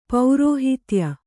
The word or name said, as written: ಪೌರೋಹಿತ್ಯ